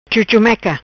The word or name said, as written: Chuchumeca